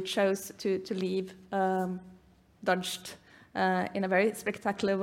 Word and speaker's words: Dozhd: used to be um, a journalist uh, working for and a TV host uh, in TV Rain, or Dozhd, as I believe it's pronounced.